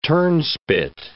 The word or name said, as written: turnspit